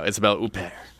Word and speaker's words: Isabelle Huppert: Isabelle Huppert.